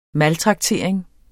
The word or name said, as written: maltraktering